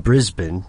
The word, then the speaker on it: Brisbane: Brisbane.